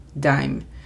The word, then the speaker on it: dime: La pronunciación fonética es D-A-I-M, dime.